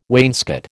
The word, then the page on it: wainscot: American English